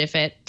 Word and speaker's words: IFTTT: It integrates with your Nest, SmartThings, Philips Hue's, IFIT integration, which is IFTTT. I pronounce it IFIT.